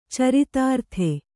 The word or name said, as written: ಚರಿತಾರ್ಥ